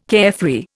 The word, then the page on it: carefree: Indian